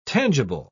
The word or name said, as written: tangible